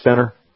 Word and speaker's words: Centre: C-E-N-T-R-E. You notice they do the English style center? Century. And I know it's pronounced center.